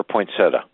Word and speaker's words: poinsettia: And how do you pronounce it? Poinsettia or poinsettia? – Poinsettia. I'm from Miami.